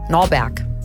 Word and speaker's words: Norberg: Er nimmt ihren Namen Norbert. Norberg. Norbert. Norberg. – Du kannst das nicht immer falsch aussprechen.